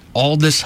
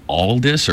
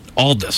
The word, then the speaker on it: Aldous: Aldous Huxley. – Aldous or Elvis? – Aldous. – Spell that. – A-L-D-O-U-S.